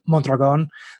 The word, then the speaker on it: Mondragon: And, you know, even though I'm technically, my last name is Mondragon. So if you roll your R's, you know, so, but a lot of people wouldn't consider me Spanish or Mexican.